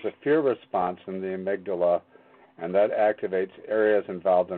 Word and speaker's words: amygdala: It comes from the part of the brain called the, I'm going to spell it, amygdala.